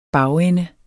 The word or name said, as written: bagende